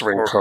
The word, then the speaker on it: Rincon: So on this list, based on my very rudimentary math skills, Skyline and Rincon, is that how you pronounce it, California people? – Yeah, it's Rincon, or, yeah.